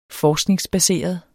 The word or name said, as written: benytter